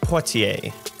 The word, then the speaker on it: Poitier: And this is episode 80, Poised Poitier. – Yeah, yeah, it's a solid French pronunciation that we can both do, and I'm very happy about that.